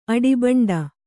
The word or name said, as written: ಅಡಿಬಂಡ